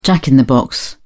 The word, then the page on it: jack-in-the-box: British English